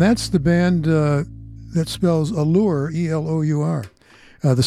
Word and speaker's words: Elour: It's pronounced Allure. Let me ask you this question. You can be honest with me, all right? You have a band, you call it Allure, but you spell it E-L-O-U-R, knowing that everybody on this planet's going to say Allure, is not going to say Allure.